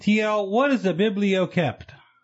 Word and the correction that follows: biblioclept: Well, actually, I said it wrong. – Oh, it's a biblioclept. – Clept. – Yeah. – I don't know. – B-I-B-L-I-O-K-L-E-P-T.